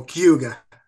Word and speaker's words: Cayuga: You can say it Cuga. You don't even have to say the U or the you just go Cayuga.